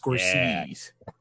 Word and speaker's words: Scorsese: I went with Martin Scorsese, and I chose Goodfellas. – It's pronounced Scorsese.